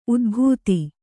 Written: ಊದಬತ್ತಿ

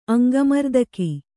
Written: ಅಂಗಮರ್ದಕ

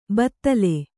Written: ಭೂತಾಲಿ

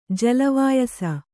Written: ಜಲವಾಯಸ

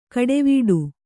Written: ಕಡೆವೀಡು

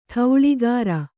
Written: ಠೌಳಿಗಾರ

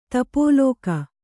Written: ತಪೋಲೋಕ

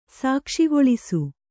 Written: ಸಾಕ್ಷಿಗೊಳಿಸು

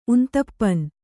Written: ಉಂತಪ್ಪನ್

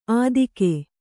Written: ಅತ್ತಿಗೆ

